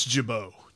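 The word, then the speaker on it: Gibaut: Did I say it right, Gibault? – I think it's Gibault.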